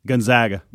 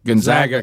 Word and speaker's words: Gonzaga: Is it Gonzaga? – Gonzaga. – Gonzaga. – Because they call them the Zags. – Gonzaga is it? – Yes.